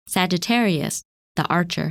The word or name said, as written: Sagittarius